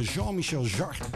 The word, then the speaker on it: Jean-Michel Jarre: Hij werkte ook nog samen in de discotijd met onder andere Jean-Michel Jarre. Ja, ook een van de, ja, toch wel de virtuose synthesizer specialisten uit die tijd. Zeg ik dat goed? Ja, dat zeg ik goed.